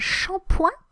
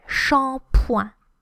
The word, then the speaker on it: shampoing: As part of your relooking, you may have un shampoing. Un shampoing, so it's shampoo, and it's spelled shampoo plus ing, but we say shampoing. Shampoing.